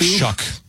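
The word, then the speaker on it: Shough: Is it Slough? Shuff? – I think, it's like Shuck, even though it doesn't look like it.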